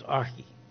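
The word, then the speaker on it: archi (ἀρχή): Now, I'm probably not doing that pronunciation much justice, but that's as good as I can do. If you want to have a sort of phonetic pronunciation, you know, but it's archi. But when you push on the little speaker and Google Translate, it says archi. So that's what I'm going with.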